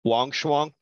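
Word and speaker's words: Wang Shuang: Wong Shuang.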